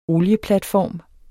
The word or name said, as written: olieplatform